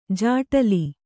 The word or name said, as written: ಜಟಿಲ